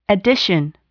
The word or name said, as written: addition